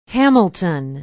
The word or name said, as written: hamilton